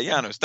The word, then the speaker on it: Janusz: Likewise with the next one, the cinematography, Best Cinematography by, and I always think I'm pronouncing his first name wrong, but is it, how would you say it? – Janusz? – Yeah, Janusz. That's that's way sexier than I would have said it.